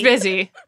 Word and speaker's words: Busy: No, Boozy Phillips. Isn't that how you pronounce it? – Busy. – It's Busy. – That's so funny. People told me for years that it's Boozy.